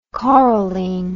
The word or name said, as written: coralene